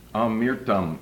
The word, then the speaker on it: Amirtham: Five people I would like to speak about are a man called Samuel Amirtham, A-M-I-R-T-H-A-M. Amirtham is the way it's often pronounced. Proper pronunciation is Amirtham.